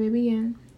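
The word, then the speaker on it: Babyxyin: You can find my Instagram at Baby Ann. That's B A B Y X Y I N. The X is silent, of course.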